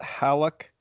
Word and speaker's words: Hallock: Brewed out of Hallock, Minnesota. Is that how you say it? Hallock? – I believe so.